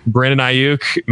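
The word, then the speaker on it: Brandon Aiyuk: Brandon Ayuk, man, 0 points for us.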